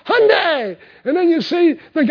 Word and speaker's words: Hyundai: And, you know, everybody's saying, and the end of the commercial is so neat, they say, and it's Hyundai. They're learning to pronounce it now, just like Sunday.